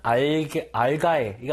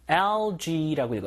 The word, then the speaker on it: algae: 읽을 때 희한하죠? 알가에. 이거 아니라 algae라고 읽어요. algae.